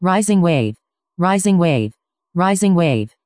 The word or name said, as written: risingwave